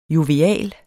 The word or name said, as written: jovial